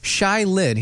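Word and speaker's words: Shai Linne: His name is Shia Lin. S-H-A-I, Shia Lin. I had to pronounce the A-I in there so people would understand. So Shia Lin, it's S-H-A-I. L-I-N-N-E. – Uh-huh. – Okay. Shy Lin.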